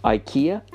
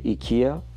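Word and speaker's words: IKEA: پس آیکیا به جای ایکیا در زبان انگلیسی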